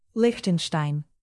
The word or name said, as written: liechtenstein